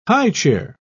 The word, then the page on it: highchair: British English